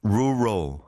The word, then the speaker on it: rural: Rural, rural, 시골. R-U-R-A-L, rural.